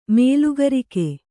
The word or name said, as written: ಮೇಲುಗರಿಕೆ